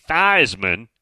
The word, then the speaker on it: Theismann: But he said, yes, I was told by somebody in the SID department back in the early 70s that it's going to be Theismann, which rhymes with Heisman.